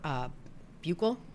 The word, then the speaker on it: buccal: The day after that, the FBI and the CBI come to her home with a warrant for buccal.